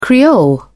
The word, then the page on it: creole: British English